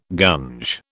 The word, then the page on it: gunge: British English